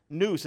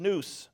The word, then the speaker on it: nous: Now, the Greek word for mind is nous. Nous. It's literally pronounced nous.